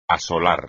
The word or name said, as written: asolar